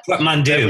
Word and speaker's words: portmanteau: What's the French word portmanteau or portmanteau? How do you say that? – Portmanteau.